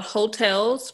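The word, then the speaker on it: Heaux Tales: This month is called Hotels, but not hotels like you stay in.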